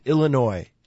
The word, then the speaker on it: Illinois: guys, it's Illinois. It's Illinois. It's silent, the S.